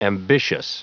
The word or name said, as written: ambitious